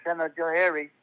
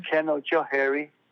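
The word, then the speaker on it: Canajoharie: Because about 3 miles from here, there's a town called Kenajohari, which is an American way to say Gunajohalage. Kenajohari, Gunajohalage. That's the same word.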